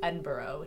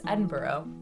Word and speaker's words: Edinburgh: So the vaults are, like, the underground tunnels beneath Edinburgh, which is, looks like Edinburgh, but the correct way to say it is Edinburgh.